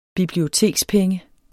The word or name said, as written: bibliotekspenge